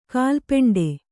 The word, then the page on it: ಕಾಲ್ಪೆಂಡೆ: Kannada